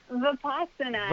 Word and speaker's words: Vipassana: Did I say that right? – Vipassana. – Vipassana, yeah, same thing.